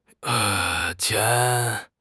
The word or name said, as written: qian2